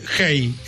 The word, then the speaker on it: Hay: En el año 2007 participé de un festival literario en Cartagena de Indias, Colombia, que se llamaba Hey, el festival. Se escribe Hey, H-A-I.